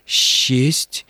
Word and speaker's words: счесть: А вот по поводу «считанной» и то же самое касается глаголов «счесть», и всевозможных других примеров, которые вы приводили, правила орфоэпии требуют, чтобы сочетания, передаваемые буквами «с» и «че», стоящими рядом друг с другом, читались как «ще». Правильное произношение «считанный», «счесть», «счастье» и так далее.